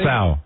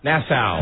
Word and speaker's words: Nassau: How do you say Nassau? – Nassau. – Nassau. – Nassau, Bahamas. – All right, hold on. We know that one's perfect.